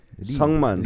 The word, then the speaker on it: Syngman: Sungman.